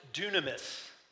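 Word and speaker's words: dunamis (δύναμις): And the word power uh, is a Greek word that is pronounced dunamis. Dunamis.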